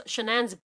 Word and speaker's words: Shanann: So as far as everyone knows, Cheyenne, how do you pronounce the name? – Shanann. – Yeah, Shanann.